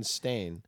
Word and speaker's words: Berenstain: it's Baron Berenstain.